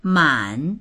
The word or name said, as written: man3